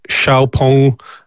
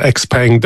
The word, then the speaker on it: XPeng: It was in Germany 2 weeks ago at the XPeng Xiaopeng, however you want to pronounce it, but in Ireland we call it XPeng.